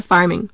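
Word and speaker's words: pharming: Farming beginning with a PH is pronounced the same as farming beginning with an F.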